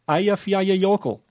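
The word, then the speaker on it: Eyjafjallajökull: Ayafyayayokel.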